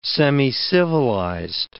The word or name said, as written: semicivilized